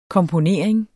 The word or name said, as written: komponering